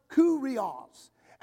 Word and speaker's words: kyrios: It's spelled K-Y-R-I-O-S, but it's pronounced kurios.